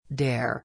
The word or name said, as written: dare